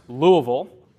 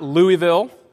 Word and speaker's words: Louisville: It is pronounced Louisville, not Louisville. If you said Louisville, get out. Just leave. Leave the state entirely.